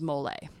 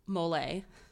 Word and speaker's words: Molei: I am doing the story of Molly Wright. And when I first read this story, I thought her name was Mole. And I say that because it's spelled M-O-L-E-I.